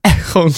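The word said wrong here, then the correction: Eoghan: Quig. – It's Owen. – Owen Quig. – Yeah, it's just an Irish spelling.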